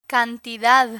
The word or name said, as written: cantidad